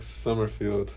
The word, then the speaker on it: Sommerfield: It's not Sommer, even though it's spelled with an O. Sommerfield. Summer. I don't know if it's because I'm German and it's supposed to be a U or it's supposed to sound like a U.